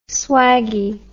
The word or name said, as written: swaggie